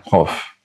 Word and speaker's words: Chof: We have the letter Chof. Chof and not Kaf. In Hebrew, we have a straightforward K sound, which is the Kaf, which everyone knows how to pronounce. And then we have a Chof. A Chof is a deep sound which comes from the very back of the throat.